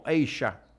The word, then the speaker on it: Asia: Porque Palmer en esa época estaba con el grupo Asia, o Asia, como se pronuncia realmente.